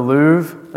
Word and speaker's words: Louvre: The Louvre, just the Louvre.